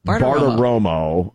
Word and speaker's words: Bartiromo: Baratomomo, Romo, Baratomomo. – Bartiromo.